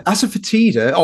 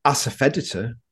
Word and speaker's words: asafoetida: Yeah, asafoetida, or as it turns out, it's asafoetida. I've been pronouncing it wrong my whole life.